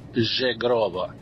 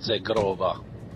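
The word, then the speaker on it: Zhegrova: Se tu vedi bene come si è scritto il nome, tra la Z e la E c'è un H, e in albanese H conta, non è come l'italiano. La Z seguita da un H si pronuncia G, come Paris Saint-Germain. Perciò si pronuncia Zegrova e non Zegrova.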